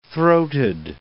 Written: throated

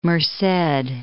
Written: Merced

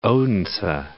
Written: Odense

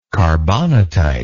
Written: carbonatite

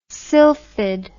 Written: silphid

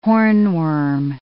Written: hornworm

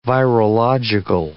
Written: virological

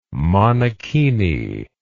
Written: monokini

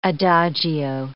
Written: adagio

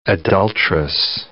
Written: adultress

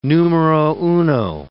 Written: numero uno

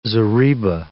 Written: zareba

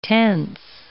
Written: tense

